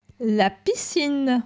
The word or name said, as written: La piscine